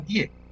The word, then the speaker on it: TIE: O lo pronunció mal y quiso decir la tía. No, no, lo pronunció bien, es la TIE.